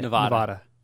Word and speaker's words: Nevada: Nevada? How do you say it? – Nevada. – We'll call it Nevada.